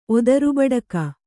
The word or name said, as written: ಒದರುಬಡಕ